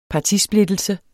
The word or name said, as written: partisplittelse